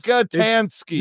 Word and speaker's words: Rockatansky: Rockatansky. That is his name. I did get it right.